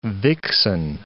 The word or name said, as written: vixen